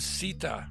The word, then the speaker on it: Sita: It was the goddess Sita.